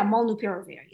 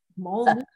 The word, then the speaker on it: molnupiravir: And let's jump into our next topic, Merck's new antiviral molnupiravir. – Ah, that's how you say it. – Well, yeah, molnupiravir, yeah. It took me a while. I had to say it over and over and over, but molnupiravir.